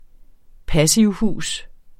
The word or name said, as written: passivhus